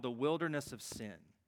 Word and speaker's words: Sin: And the text says here that they enter into the wilderness of sin. Now, I recognize in Hebrew it's probably pronounced seen.